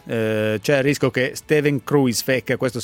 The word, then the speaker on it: Kruijswijk: Come si pronuncia? – Kruijswijk. – Quindi ecco, così. Quindi siccome tu lo pronunci Kruijswijk, per noi sarà così.